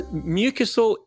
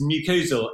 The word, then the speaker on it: mucosal: So mucosal immunology, is that something to do with mucus? Is that right? – No, it's mucosal.